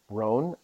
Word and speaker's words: Rohn: So one of these quotes that I really love, the gentleman's name is Jim Rohn. It's spelled Ron, Rohn. So it's like John, but with an R.